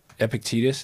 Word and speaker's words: Epictetus: It makes me think of Epictetus. Just this is Stoic. Mm-hmm. – That was great pronunciation. I would have butchered it.